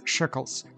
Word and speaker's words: shekel: Now we pronounce this word as shekels, so 2 shekels of silver.